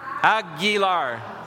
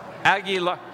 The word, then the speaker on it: Aguilar: A-G-U-I-L-A-R? Say it again. Aguilar? Can you say it loud? Louder? Aguilar. Aguilar. Aguilar. Okay, A-G-U-I-L-A-R. Aguilar. Aguilar. Aguilar. Okay, Aguilar. Thank you. I humbly acknowledge I don't know Spanish. Aguilar.